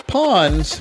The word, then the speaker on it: Pawns: You've been listening to Jondi and Spesh present Loöq Radio with Prawns. – Yeah, I'm still laughing about my mispronunciation of Pawns by Casey Bourne. It is not prawns, it's pawns.